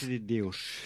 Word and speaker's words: Cidade de Deus: Cidades de Deus, ne? So spricht man es aus. – Cidade de Deus. – De Deus, genau, so.